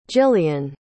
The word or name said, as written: jilian